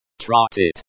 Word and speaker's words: Тратить: The stress is on the first syllable. Trot it.